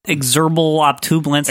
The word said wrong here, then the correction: external occipital protuberance: that I can never... – External occipital protuberance. – Thank you.